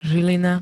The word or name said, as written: zilina